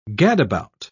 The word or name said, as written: gadabout